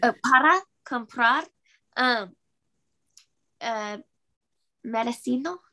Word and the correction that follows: medicina: Am I saying that right? – Medicina, está bien.